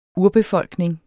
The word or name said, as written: URBEFOLKNING